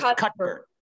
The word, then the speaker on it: Cuthbert: So if Aldi's comes out with their, they have, you call it Cutbert? – Cutbert. Yeah. Cutbert. Yeah. – So you don't pronounce the TH, th, you pronounce it T. So it's Cutbert.